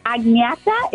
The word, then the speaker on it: Agneta: Agneta is the correct way to say it. But to be honest, no one can ever pronounce it.